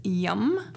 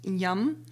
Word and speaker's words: Yam: And the uh, bija mantra for the heart chakra is yum, which is spelled Y A M and pronounced yum.